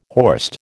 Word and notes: horst: American English

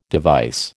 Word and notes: device: American English

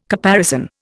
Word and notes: caparison: Indian

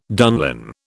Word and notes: dunlin: British English

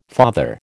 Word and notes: father: American English

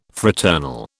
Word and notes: fraternal: British English

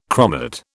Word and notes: chromate: British English

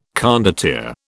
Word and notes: condottiere: British English

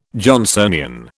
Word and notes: Johnsonian: British English

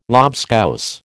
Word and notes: lobscouse: American English

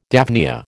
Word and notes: daphnia: American English

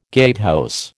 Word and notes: gatehouse: American English

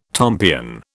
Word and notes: tompion: British English

